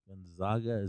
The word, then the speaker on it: Gonzaga: Gonzaga. Is that how you say it? – Yeah.